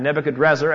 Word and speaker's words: Nebuchadrezzar: And so Nebuchadnezzar brought them back, or Nebuchadrezzar, as his name really is pronounced, brought the vessels of gold back.